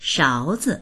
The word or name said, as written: shao2zi